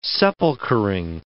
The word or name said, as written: sepulchering